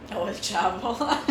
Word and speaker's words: El Chapo: El Chapo.